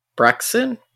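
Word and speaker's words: Braixen: There is a the four star raid bonus thing that they do for the in-person raids with Brexen.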